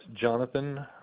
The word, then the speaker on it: Jonathan: It's Jonathan McClendon.